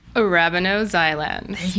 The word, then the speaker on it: arabinoxylans: Arabinoxylans.